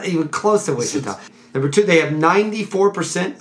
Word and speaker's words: Ouachita: Wichita. – Wait, what? – Wichita. – That's how they pronounce it? – Yes.